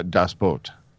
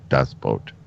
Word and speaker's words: Das Boot: No, but internationally, Das Boot. B-O-O-T, pronounced in German, boat. Das Boot.